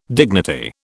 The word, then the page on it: dignity: British English